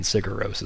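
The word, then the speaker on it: Sigur Rós: Cigarose is playing.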